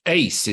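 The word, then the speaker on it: Uys: But I think it's Ace is how you say okay. It. A-C-E, but it's spelt U-Y-S, I think, isn't it? Which is that Afrikaans name.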